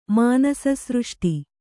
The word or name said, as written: ಮಾನಸಸೃಷ್ಟಿ